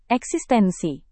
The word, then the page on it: eksistensi: indonesian